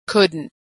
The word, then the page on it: couldn't: American English